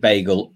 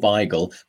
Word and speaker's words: bagel: I know that's why we always talk about whether we pronounce it bagel or beigel.